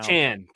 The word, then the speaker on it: Achane: Take away the carries from Mostert and Achane or whatever his name is now. – Achane, come on. – Achane. I corrected myself within 1 second, so pronunciation police put the cuffs away.